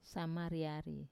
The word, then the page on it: samariari: wamesa